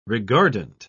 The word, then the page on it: regardant: British English